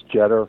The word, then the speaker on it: Jeter: It's spelled like Derek Jeter, but pronounced Jeter.